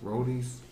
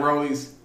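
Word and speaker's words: Roti: I heard of Roddy's, how do you pronounce that restaurant? Roddy's, R-O-T-I.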